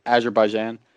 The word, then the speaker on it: Azerbaijan: Azerbaijan. – Oh, that's how you pronounce it? Oh, I butchered that so bad.